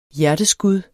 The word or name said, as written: hjerteskud